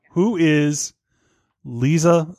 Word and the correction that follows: Liza: Who is Lisa Minnelli's mother? – Liza Minnelli? – Liza Minnelli, thank you.